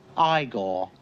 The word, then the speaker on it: Igor: No, it's pronounced Igor. – But they told me it was Igor.